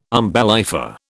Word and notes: umbellifer: British English

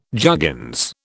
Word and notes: juggins: British English

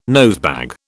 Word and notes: nosebag: British English

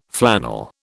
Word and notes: flannel: British English

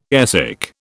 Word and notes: airsick: British English

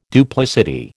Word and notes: duplicity: American English

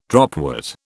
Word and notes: dropwort: British English

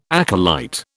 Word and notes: acolyte: British English

